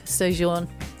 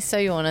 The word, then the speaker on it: sojourn: So it's a hard J. – Sojourn. Or is it like a sort of a semi-soft? – Like a zhuzhi. Sojourn. – Sojourn. – A sojourn.